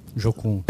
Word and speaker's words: Jocum: E tem um amigo que pregou lá, ele falou o seguinte, olha, é, um pessoal do Jucum, já falar? Jocum. É, sim. Jocum, falei errado.